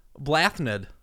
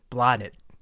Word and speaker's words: Blathnaid: Blathnid. – You are close. You are actually really close. Take out the TH. It is pronounced blonded.